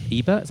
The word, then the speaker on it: Ebert: So film critic Roger Ebert, Ebert, is that how you pronounce his name? – Ebert.